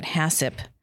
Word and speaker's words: HACCP: Hazard analysis, critical control point, that you'll see that abbreviated HACCP, and they pronounce that HACCP.